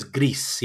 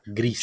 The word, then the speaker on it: Gris: Is that how you pronounce that? – I believe it is Gris. Yeah, Gris. – G-R-I-S. Yes, another game that came off the Humble.